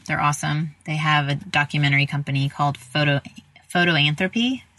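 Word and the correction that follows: Fotolanthropy: Is that how did I say it right? – Yes. Photolanthropy. – Photolanthropy. – Yes. – Yeah. – And it's spelled F-O-T-O lanthropy. It's not P-H.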